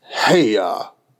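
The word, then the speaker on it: Haigha: His name is Heya. – He pronounced it as though to rhyme with mayor.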